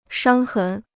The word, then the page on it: 伤痕: shāng hén